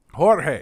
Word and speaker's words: Jorge: Well, his younger brother is, I believe, is that pronounced Jorge? – Jorge.